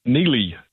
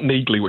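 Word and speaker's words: Neagley: It's the first spin-off from that monster action thriller series, and it focuses on his occasional partner, Neely, and it's not Neagly, which I thought it was as well, despite the hard G in her name, Neely.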